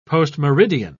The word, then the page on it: postmeridian: British English